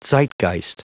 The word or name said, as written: zeitgeist